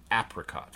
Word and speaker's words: apricot: The Oracle pronounces the initial vowel like the A in cat. So, apricot.